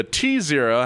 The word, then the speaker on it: T-Zero: I think it's pronounced zero. – Zero. – Zero. – The T-Zero had a 150 kilowatt motor powered by the all-new lithium-ion batteries that put out the equivalent of 201 horsepower.